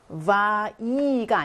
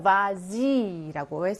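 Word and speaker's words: vas-y: 이 va 뒤에 중성 대명사가 오게 되면 발음 때문에 s를 생략하지 않아요. 여기를 보시면 va-i가 아니고요. va-zi라고 해서 이때는 s가 다시 살아나게 됩니다.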